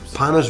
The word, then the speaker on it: Panos: Panos Vlahos is my name.